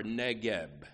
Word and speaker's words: Negev: And then this expression, like streams in the Negev, or Negev. It's a B in Hebrew, better pronounced as a V in English.